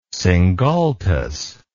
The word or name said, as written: singultus